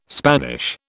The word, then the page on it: Spanish: British English